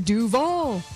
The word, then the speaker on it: Duval: Also, you didn't say it right. You have to go Duval.